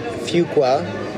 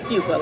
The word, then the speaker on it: Fuqua: And it is Fuqua, like F-E-W.